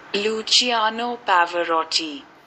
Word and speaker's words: Luciano Pavarotti: Luciano Pavarotti.